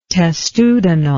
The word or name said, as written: testudinal